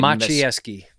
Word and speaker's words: Maciejewski: I don't even know how to say your last name. – Maciejewski. – Maciejewski. I was going to totally butcher that.